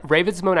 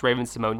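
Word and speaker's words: Raven-Symoné: "Now, I didn't do that because at a very young age, I was told to tell everyone that my name was Raven Simone, and it just never left my body, except when I go to France, French-speaking countries, and I'm like, yeah, it is spelled that way, and that's how it should be pronounced."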